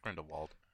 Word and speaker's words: Grindelwald: Grindelwald. Is that how you say it? Yeah, Grindelwald.